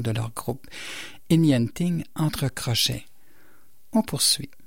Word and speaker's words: Ingenting: Quand je vous disais que le site Forvo, c'était bien pratique pour s'assurer de la bonne prononciation, je viens d'aller voir Inyanting. J'avais prononcé Ingenting, mais c'est Inyanting.